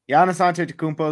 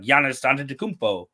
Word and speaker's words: Giannis Antetokounmpo: Giannis Antetokounmpo is an amazing player.